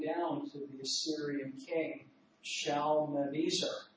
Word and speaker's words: Shalmaneser: It's called the Black Obelisk of Shalmaneser III. Did I say that right, Mitch? Shalmaneser? – I usually pronounce it Shalmaneser. – Shalmaneser, okay. I will take your advice.